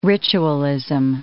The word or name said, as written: ritualism